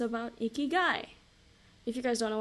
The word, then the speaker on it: ikigai: Pronounced ikigai, is a Japanese concept that means a reason for being.